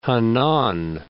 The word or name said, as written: Henan